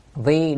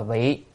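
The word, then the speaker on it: vi (為): Vi đạo tác thiện, chữ này phải đọc là vi, không đọc là vị. Nếu đọc là vị là vì rồi, còn đọc vi là làm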